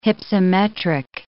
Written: hypsometric

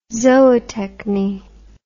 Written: zootechny

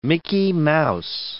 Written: Mickey Mouse

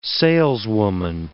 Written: saleswoman

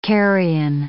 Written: carrion

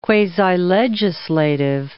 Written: quasi-legislative

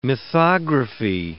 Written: mythography

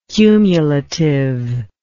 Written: cumulativeness

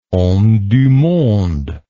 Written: homme du monde

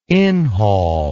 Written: inhaul

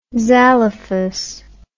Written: zalophus

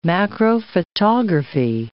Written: macrophotography

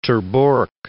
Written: Ter Borch

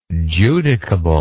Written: judicable

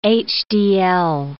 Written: HDL

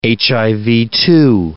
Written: HIV-2

